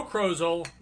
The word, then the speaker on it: Krozel: Joe Krozel rhymes with puzzle.